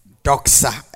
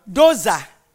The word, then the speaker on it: doxa: But the thing says D-O-X-A. How do you want to pronounce it? Doza. Yeah. Doxa. Yeah.